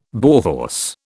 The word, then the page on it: Bauhaus: British English